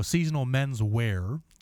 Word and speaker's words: Seasonal Menswear: That's uh, Johnny Automatic with She Said, and uh, starting that block off uh, for the Subscriber Choice podcast was Seasonals Menswear, and I keep pronouncing that with a plural S. Sorry, it's my South Side upbringing. We pluralize everything. Jewels, Kmart's, what what have you. – Uh, so Seasonal Menswear, that's better.